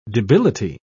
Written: debility

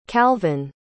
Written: calvin

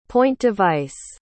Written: point-device

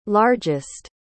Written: largest

